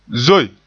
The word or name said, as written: zoe